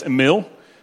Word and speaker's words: Emil: Okay, so if I would say my name is Swedish, it would be Emil Brinte. It's a good name. My name's Emil. That's the easiest way to say it in English.